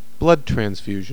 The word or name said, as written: blood transfusion